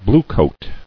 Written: Bluecoat

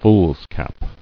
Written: Foolscap

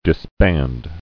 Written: Disbanding

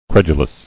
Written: Credulously

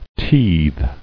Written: Teethed

